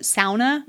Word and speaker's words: sauna: Or I've heard the correct way to say sauna is not sauna, but sauna.